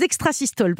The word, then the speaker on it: extrasystoles: Alors, Jimmy, les extrasystoles, parce que je crois que c'est comme ça plutôt qu'il faut le prononcer.